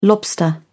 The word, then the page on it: lobster: British English